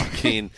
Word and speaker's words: Joaquin: and Jacqueline. Is that how you pronounce it? – Joaquin Phoenix. – Joaquin.